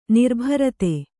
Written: ನಿರ್ಭರತೆ

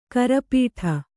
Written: ಕರಪೀಠ

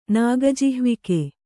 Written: ನಾಗಜಿಹ್ವಿಕೆ

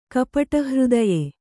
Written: ಕಪಟಹೃದಯೆ

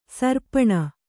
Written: ಸೀರ್ಪನಿ

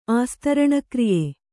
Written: ಆಸ್ತರಣಕ್ರಿಯೆ

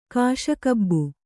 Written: ಕಾಶಗಬ್ಬು

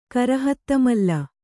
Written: ಕರಹತ್ತಮಲ್ಲ

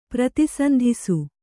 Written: ಪ್ರತಿಸಂಧಿಸು

